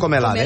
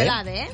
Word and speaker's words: Comelade: Comelade. – Comelade.